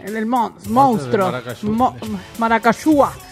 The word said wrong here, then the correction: maracuyá: Maracayúa. – Maracuyá. ¿Cómo se dice? – Maracuyá. – Maracuyá. Novio para la mujer. Maracuyá o maracuyá. – Para mí, maracuyá. – Con acento.